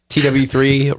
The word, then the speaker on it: TW3: It says, TW3 rhymes with CBD.